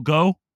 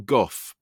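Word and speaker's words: Gough: And I think joining him in like kind of the Hammer adjacent is how do you say his last name? Michael Goh? – I think it's Michael Goff, but I could be wrong. – Goff. – I've always said Goff.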